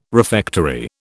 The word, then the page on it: refectory: British English